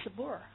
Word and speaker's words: spur: Stir, spur. 啊，注意这个发音啊。